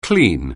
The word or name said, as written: clean